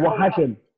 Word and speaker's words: Oaxacan: Oh, is that how you say it? – Oaxacan. – Oaxacan. – From Oaxaca.